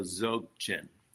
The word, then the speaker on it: Dzogchen: The D is silent, so Dzogchen.